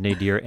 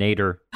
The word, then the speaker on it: nadir: I mean, look, let's say you're at the nadir of a relationship, and I will point out that I did look on Merriam-Webster, which both recognizes nadir and nader as acceptable pronunciations.